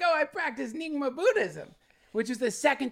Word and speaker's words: Nyingma: suddenly Nyingma, is that how you pronounce it? – Yeah, yeah.